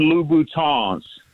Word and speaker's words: Louboutin: And then most importantly, so the women that listen to the show don't start tagging you on YouTube, they are Christian Louboutins. You got to pronounce it with the French.